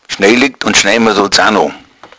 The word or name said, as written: schnee